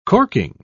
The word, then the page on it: corking: British English